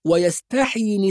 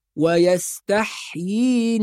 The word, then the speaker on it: ويستحيي: أيضا المد في كلمة طائفة، نحافظ على المد، يذبح أبناءهم ويستحيي نساءهم، بعض الناس لما يقرأ يقول ويستحيي نساءهم، ويستحيي هذا خطأ، فحرف الحاء ساكن وبعده ياء مكسورة، والياء المكسورة بعدها ياء صغيرة، تمد مد طبيعي حركتين، يبقى نحافظ على النطق الصحيح فنقول ويستحيي نساءهم إنه كان من المفسدين.